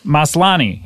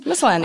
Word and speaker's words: Maslany: Maslany. Nope. – Maslany. – Maslany. Yeah, I did it right. – Yeah. – Right? – Maslany. – Did I do it right the first time? – Maslany.